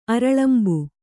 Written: ಅರಲಂಬು

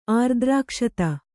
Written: ಆರ್ದ್ರಕ್ಷತ

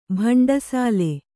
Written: ಬಂಡಸಾಲೆ

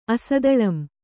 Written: ಅಸದಳಂ

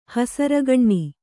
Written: ಹಸರಗಣ್ಣಿ

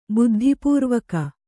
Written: ಬುದ್ಧಿಪೂರ್ವಕ

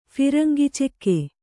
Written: ಫಿರಂಗಿಚಕ್ಕಿ

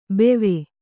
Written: ಬೆವೆ

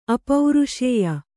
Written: ಅಪೌರುಷೇಯ

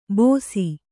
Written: ಬೋಸಿ

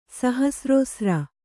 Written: ಸಹಸ್ರೋಸ್ರ

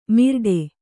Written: ಮಿರ್ಡೆ

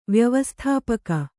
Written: ವ್ಯವಸ್ಥಾಪಕ

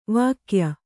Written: ವಾಗ್ಯ

